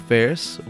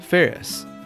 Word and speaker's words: Phares: A lot of you guys know that time as the SJP times, or Scott Joseph Ferris, which is my birth name. My birth name is Scott Joseph Ferris, which my last name is spelled P-H-A-R-E-S. Commonly mispronounced as Phrase, Fars, Pijeres, Pijeres, and all sorts of different things.